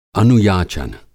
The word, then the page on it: अनुयाचन: Hindi